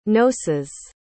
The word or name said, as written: knossos